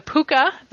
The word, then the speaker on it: Pooka: The Puka, that's pronounced Puka, just as Jimmy Stewart said it in Harvey.